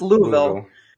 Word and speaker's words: Louisville: So it's pronounced Louisville, and I might even be getting that wrong.